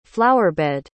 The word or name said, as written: flowerbed